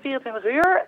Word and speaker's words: 24H: Zeg, is die, ja, hoe spreek je nou eigenlijk uit? Is het 24H of 24 uur? – Ik zeg altijd 24 uur en ik heb ook nog een heleboel mensen die ik hoor die zeggen altijd 24 hours. En dat mag ook.